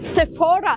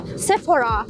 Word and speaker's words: Sephora: что я целый год хожу в Sephora, но оказалось, что это французский бренд, а во Франции ударение должно падать на последний слог, поэтому Sephora.